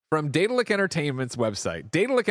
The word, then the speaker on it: Daedalic: At this point, Google's pronunciation of Daedalus is, Daedalus. So then it would be Daedalic.